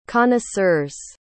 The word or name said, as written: connoisseurs